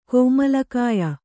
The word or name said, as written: ಕೋಮಲಕಾಯ